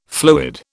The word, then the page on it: fluid: British English